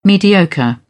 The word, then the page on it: mediocre: British English